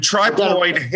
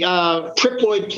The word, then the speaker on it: triploid: The triploid plants. – You call it trip or tri? Obviously, I don't even know how to pronounce it. So it's triploid.